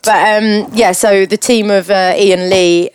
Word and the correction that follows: Elan Lee: or Elan, perhaps. Perhaps yeah. – I pronounced that wrong. Elan Lee and Matthew Inman, who is the Mr. Oatmeal.